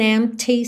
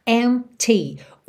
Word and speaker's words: empty: There's just an empty space. Empty. O P é mudo, tá? E o T é marcadinho. Empty, empty space.